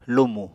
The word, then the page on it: lumù: Apatani